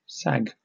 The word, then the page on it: sag: British English